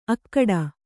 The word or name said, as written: ಅಖೋಟ